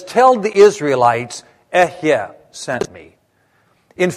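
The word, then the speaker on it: Ehye (אהיה): So here's how you say I am. Ehye. Everybody say ehye. Two syllables, ehye.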